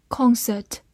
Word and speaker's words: concert: Concert, C O N C E R T, concert, C O N 发空，C E R T cert, concert, concert。美式发音字母 O 的口型比较大，另外 R 也有个卷舌，concert。